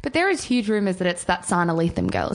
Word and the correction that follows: Lathan: Is that how you say her name? – She's second favorite. Sana Lathan, I think it is.